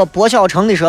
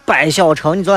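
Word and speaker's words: 柏小成: 这个叫个薄小成的说，我的昵称读作白小成，你昨天读错了。上了大学只有一个老师读对了，太尴尬了。姓名当中这个字念柏，啊，柏油的柏。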